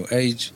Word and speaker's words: AG: pisze się AG, mówi się Age, w zasadzie po litewsku grupę Age, dzisiaj jesteśmy troszeczkę w innym składzie, więc troszeczkę inny materiał z Legitą, no i tak...